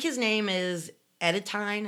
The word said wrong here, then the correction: Etienne: Is that how you would pronounce that? Edetine? – Etienne, if it's French.